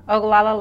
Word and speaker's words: Oglala: Is it Oglala? – Oglala Lakota. – Oglala Lakota. Great.